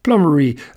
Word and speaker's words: plummeri: Plummeri ends in A-E, and if you took or suffered through 2 years of middle school Latin, that A-E should be pronounced I. But in botanical Latin, that A-E is pronounced E, plummeri.